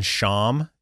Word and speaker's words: sham: The word here in Hosea 6:7 for there is just the very common sham is how you say it in Hebrew.